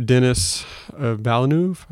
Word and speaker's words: Denis Villeneuve: Denis Villeneuve, I believe is how you pronounce it, Canadian director.